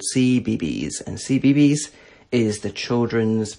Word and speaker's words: CBeebies: So it's called CBeebies, which is spelled C-B-E-E-B-I-E-S.